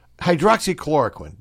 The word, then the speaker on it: Hydroxychloroquine: Hydroxychloroquine.